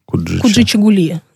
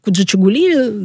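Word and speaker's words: Kujichagulia: На самом деле я не скажу, как именно какие правила расставления ударений, но вот Куджи-Чугули, да, вот так произносится.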